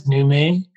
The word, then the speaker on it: Numei: Yeah, Her name is Nume, and it's just spelled like new and then May, or it's said like that, Nume, but it's spelled N-U-M-E-I.